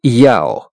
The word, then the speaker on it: Яо: Произносится как «Яо».